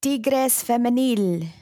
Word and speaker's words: Tigres Femenil: How do you say that? I'm probably butchering that. – Tigres Femenil. – There you go. There you have it.